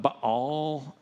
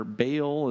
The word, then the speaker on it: Baal: And then we have these different idols we call it Baal, or Baal is the other way that we pronounce it, and the Asherah poles are the shrines that people would go to and worship.